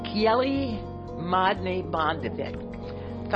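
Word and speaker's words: Kjell Magne Bondevik: And that is an interview with, and I'm going to murder the pronunciation, but J is pronounced Y. Kjeli Madne Bondevik, founder of the Oslo Center about 20 years ago.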